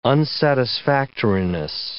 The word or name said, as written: unsatisfactoriness